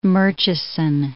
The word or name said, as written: Murchison Falls